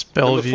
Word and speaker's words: Bellevue: Bellevue. – It's Bellevue.